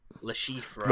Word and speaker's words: Le Chiffre: There is an R in it, but they don't say it in film. – C-H-I-F-F-R-E. Le Chiffre.